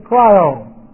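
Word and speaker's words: cryo (χρίω): The root is cryo. C-H-R-I long O. Cryo.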